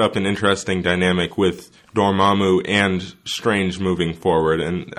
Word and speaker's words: Dormammu: I mean, we sort of knew throughout the whole entire time that Cassilius was working for, Dormammu. That's how you pronounce his name.